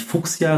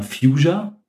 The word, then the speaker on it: Fuchsia: Und zwar, wie ich heute gelernt habe, nicht Fuchsia, sondern Fuchsia. Fuchsia. Fuchsia. Fuchsia. – Ja. Wegen Future. Oder Fuchsia. ja. ja. ja, ja, es ist, also so ist die englische Aussprache, ja.